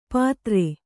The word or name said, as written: ಪತ್ರೆ